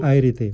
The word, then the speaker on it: Planchart: Se dice... Planchart. – Como plancha, y termina art. – Art. – Como arte, Planchart.